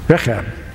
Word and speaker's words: Rechab: And they said, no, because our father, Rechab, that's how you say it, he commanded us never to build a house, never to drink anything from the vine, and to live in tents.